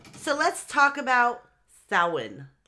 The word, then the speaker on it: Samhain: In modern times, Samhain, a Gaelic word pronounced Samhain,